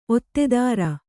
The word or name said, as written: ಒತ್ತೆದಾರ